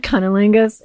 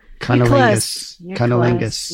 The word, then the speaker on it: cunnilingus: Yeah, like, I think if you use that word, cummingus, it would like boost our ratings for sure. Like, just keep saying it. – Cunnilingus, am I saying it right? – Cunnilingus.